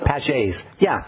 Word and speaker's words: Paget's: You said Paget's disease, correct? – Is that how you say it? Thank you. – Paget's, yeah, P-A-G-E-T.